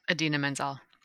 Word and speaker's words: Idina Menzel: How do you say her name? – Idina Menzel.